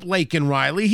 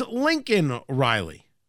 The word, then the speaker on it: Laken Riley: When he brings up Laken Riley, he doesn't say Laken Riley's name properly. He refers to her as Lincoln Riley.